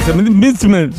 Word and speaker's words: msemen: Es una palabra complicada, decías M-S-E-M-E-N. Misemen, misemen, ¿vale? Y se pronuncia como lo ha hecho Hakima.